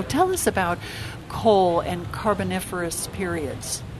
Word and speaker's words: Carboniferous: Am I saying that right? Carboniferous. – Carboniferous, okay, yeah.